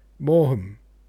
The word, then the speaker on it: Maugham: You said it correctly, but it still sounds like someone imitating a deaf person speaking. Maugham. Maugham. Right? Maugham. Maugham. Maugham. It's a dumb name. M-A-U-G-H-A-M. Dumb.